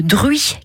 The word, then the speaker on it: Druyes: Ce sera celui de Druy, qui s'écrit D-R-U-Y-E-S, comme vous le savez.